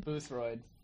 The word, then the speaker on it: Boothroyd: Dude, Ian Boothroyd. How do you say his name? – Boothroyd. – Boothroyd. Oh, that's fun to say.